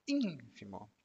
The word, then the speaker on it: ínfimo: E aqui a tônica, a sílaba forte é a primeira ínfimo significa mínimo, insignificante.